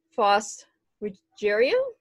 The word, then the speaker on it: Faust Ruggiero: I'm here with author Faust Ruggiero.